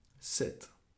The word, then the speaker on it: sept: Sept. – And there's a silent P in there, so it's spelled S-E-P-T.